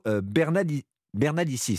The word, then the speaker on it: Bernalicis: Et puis on notera aussi la venue de l'ancienne ministre de la Justice de Nicolas Sarkozy, Rachida Dati, pour une discussion avec Hugo Bernalicis. C'est comme ça que ça se prononce.